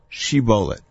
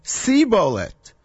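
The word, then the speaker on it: shibolet (שיבולת): Say the word shibolet. Vayomer, the guy trying to cross says, sibolet. Velo yachin lidaber ken, because he is not able to pronounce it correctly.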